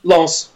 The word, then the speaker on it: Lens: Lens.